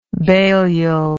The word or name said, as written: baliol